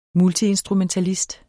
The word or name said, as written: multiinstrumentalist